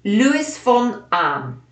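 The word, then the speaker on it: Luis von Ahn: Luis von Am.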